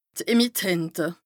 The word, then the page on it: d' Emittente: Bas Rhin